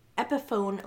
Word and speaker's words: Epiphone: And then she's like, and we also have a Epiphone Les Paul guitar for each of you, if I said it right.